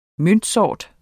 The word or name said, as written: møntsort